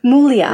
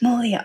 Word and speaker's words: Muglia: My family says Mulia. – Mulia. – Where the G is silent. – Mulia.